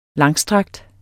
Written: langstrakt

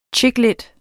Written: chicklit

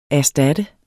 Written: erstatte

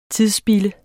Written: tidsspilde

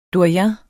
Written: DOYENEN

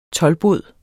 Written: Toldbod

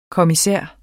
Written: kommissær

